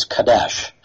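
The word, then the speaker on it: kadash (קדש): In Hebrew, the word here for sanctified is kadash. Kadash, spelled with a Q.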